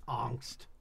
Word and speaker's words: Angst: Is it pronounced Angst? – Angst. – Angst. – Angst. It's German.